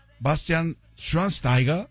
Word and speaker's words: Bastian Schweinsteiger: Me sorprendiste con tu pronunciación del Antonio. Antonio, fíjate que cuando estaba en tele yo, en el Canal 4, en los domingos en la noche, muy todo eso, Bastian Schweinsteiger.